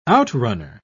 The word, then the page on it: outrunner: British English